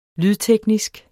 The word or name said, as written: lydteknisk